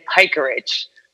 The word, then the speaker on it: Pajkuric: So it was K and then Pikerich, which is spelled like Patch Kirk. It's Croatian. It's pretty crazy.